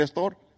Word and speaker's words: store: Pero no se pronuncia con S, sino con E. El store. Y le quedó el store.